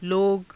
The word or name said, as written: loga